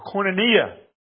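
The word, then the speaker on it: koinonia (κοινωνία): And this is, well, some of us men learned yesterday, the word for fellowship here is koinonia. Or koinonia, if you pronounce it correctly. Got it right, I hope? Koinonia, yes.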